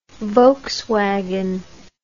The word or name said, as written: volkswagen